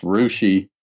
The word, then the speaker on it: Russia: The place in Ohio that spelled Russia but pronounced Rushi, I can kind of see that too, except in Ohio, I would expect it to become something like Rusha.